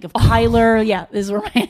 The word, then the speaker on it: Kyler: I kept saying his name wrong. – I know. – I thought it was Kyle. – Well, it's the kind of name that someone needs to correct you on.